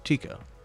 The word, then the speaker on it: Tycho: I believe it's pronounced Tycho.